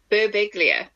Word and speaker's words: Birbiglia: You say his name. It's Mike Birbiglia. – There we go, that's bad.